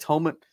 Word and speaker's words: Tominaga: How do you say it? – Tominaga. – Tominaga. Sorry. Tominaga.